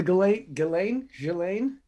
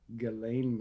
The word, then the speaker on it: Ghislaine: The Ghislaine? Gilles. – I don't know. I've been saying Gilles. – I just know the S is not pronounced. That's what I know about that name.